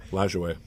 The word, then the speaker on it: Lajoie: Oh, Lajoie. – Lajoie. Yeah, you got to say it correctly.